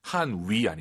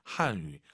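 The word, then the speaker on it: 汉语: 한위 아니고 입술 동그랗게 말아보세요. 동그랗게. 으, 으, 한유. 한유.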